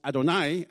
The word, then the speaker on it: Adonai: Das Wort mit 4 hebräischen Buchstaben, das ich wie alle Juden als Adonai ausspreche, ist fast unmöglich, so wie es geschrieben steht, auszusprechen.